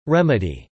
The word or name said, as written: remedy